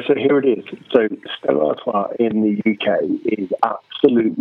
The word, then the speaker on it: Stella Artois: Stella Artois, is that how it's said? – Yeah.